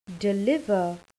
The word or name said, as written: deliver